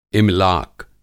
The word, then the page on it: इमलाक: املاک